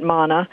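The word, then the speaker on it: manna: So we were talking just before the break about, as you pronounced it, mana. I'm going to take your pronunciation. It's much prettier.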